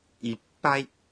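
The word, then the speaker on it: 이빠이 (いっぱい): 이빠이의 발음이 좀 어렵죠? – 이빠이. – 얘는 초금이 들어있습니다. 이 초금은 작은 쯔로 표시를 하고요. 한 박자분의 숨을 멈추면 됩니다.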